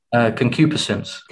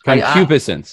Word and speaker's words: concupiscence: And that gets into this idea, cocubescence. I'm probably still saying it wrong. It's a new word for me. Yeah, you go for it. You say it for everyone. – Concupiscence. – Concupiscence. There we go.